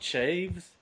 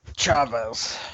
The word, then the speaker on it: Chavez: Richard Chaves? – Chavez? – I guess. – Chaves. Is that what it's spelled? – Chaves, yes. – Chavez. – Yeah, that's it's Chavez, Mexican. Dude.